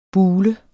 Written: bule

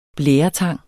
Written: blæretang

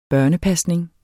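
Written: børnepasning

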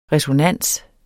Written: RESONANSERNES

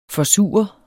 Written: forsure

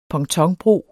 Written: pontonbro

